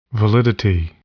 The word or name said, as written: validity